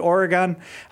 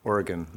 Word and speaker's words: Oregon: And it's pronounced Oregon, not Oregon.